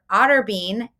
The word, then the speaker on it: Otterbein: And she has been appointed adjunct faculty for Otterbein. Did I say that right? – Yes. – Otterbein University? Good. Otterbein.